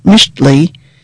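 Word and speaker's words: Mixtli: The poetic name they chose, pronounced Mishtli, means cloud in Nahuatl, the language of the Aztecs, and some of their descendants.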